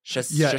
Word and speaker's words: Chastain: Chastain. – Well, that's actually the correct pronunciation. – Chastain. – Everyone mispronounces it, but she stopped correcting people.